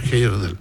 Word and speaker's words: Heyerdahl: Aku Thor Heyerdahl. – Thor Heyerdahl. – Muchas gracias por estos minutos. – Muy bien, que estén muy bien. – Se pronuncia más fácil que cómo se escribe Heyerdahl.